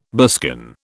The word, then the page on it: buskin: British English